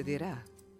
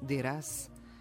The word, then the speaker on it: Duras: Marguerite Duras, kako bi trebalo da izgovaramo prezime ove poznate francuske umjetnice, odnosno Duras, kako je obično kod nas prevedeno, rođena je 1914.